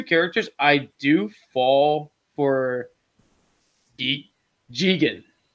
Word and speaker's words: Jigen: It's called, his name is Daisuke Jigen.